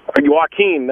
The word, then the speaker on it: Joaquin: I don't know how to say his first name. – Oh, okay. All right. Joaquin. – Joaquin, that's it. That's right. How you say it.